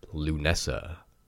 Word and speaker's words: Lughnasadh: And the one I just realized I butchered earlier, all I had to do was look up the pronunciation key, and it's pronounced Lunessa, which is on August 1st. It is spelled, let me just clarify this, L-U-G-H-N-A-S-A-D-H. Yes, that does spell Lunessa. In retrospect, absolutely it does. My modern English brain does not read it as Lunessa.